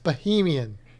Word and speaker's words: Bahamian: It's pronounced an A. I know. We've been through this. It's pronounced Bahamian. – Okay. Anyway. I was just saying it the way that it's spelled. – Okay. Well, I'm saying it the way the people that live there say it.